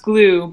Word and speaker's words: Gloo: So it's pronounced glue, but it's GLOO.